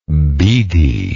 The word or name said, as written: beedi